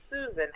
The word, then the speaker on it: Suzan: So my first name, even though it's spelled with a Z, it's pronounced Susan, S U Z A N Z, so you can remember me.